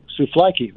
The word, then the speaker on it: souvlaki: Now, did you say, am I saying it right? Souvlaki? Did I say it right? – Souvlaki. Very good. Yes.